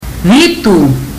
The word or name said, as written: vitu